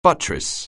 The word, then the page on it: buttress: anglais